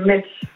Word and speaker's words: Metz: Metz.